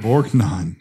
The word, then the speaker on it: Borgnine: Borgnine.